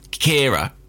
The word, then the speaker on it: Ciara: In England, people are pronouncing that name about five different ways. I've heard Kira, Kiara, Ciara, Sierra, and others. But well, we're going with Kira, are we? – Yeah, well, that's how you pronounce it. It's an Irish name.